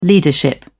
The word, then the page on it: leadership: British English